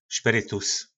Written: spyrytus

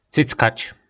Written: cyckac

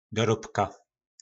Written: dorobka

